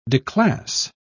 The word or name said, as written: declass